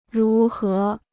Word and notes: 如何: rú hé

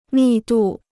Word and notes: 密度: mì dù

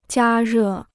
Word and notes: 加热: jiā rè

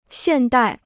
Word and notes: 现代: xiàn dài

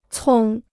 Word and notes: 葱: cōng

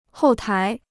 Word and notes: 后台: hòu tái

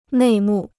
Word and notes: 内幕: nèi mù

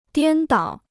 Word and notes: 颠倒: diān dǎo

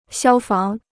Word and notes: 消防: xiāo fáng

